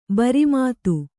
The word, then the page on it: ಭ್ರಮಿತ: Kannada